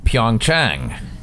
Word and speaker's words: Pyeongchang: Pyeongchang.